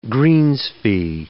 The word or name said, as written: greens fee